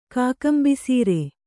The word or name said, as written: ಕಾಕಂಬಿಸೀರೆ